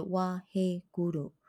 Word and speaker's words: Waheguru: 好，那接下来呢，我们要唱到这个 mantra 啊，发音是哇嘿咕噜。